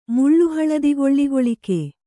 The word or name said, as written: ಮುಳ್ಳುಹಳದಿಗೊಳ್ಳಿಗೊಳಿಕೆ